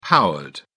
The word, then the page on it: powered: British English